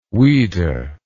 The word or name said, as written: ouida